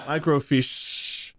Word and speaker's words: microfiche: Microfiche. Emphasis is on the sh.